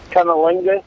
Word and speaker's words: cunnilingus: Is that how that's pronounced? – Cunnilingus? – Yes.